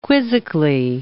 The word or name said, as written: quizzically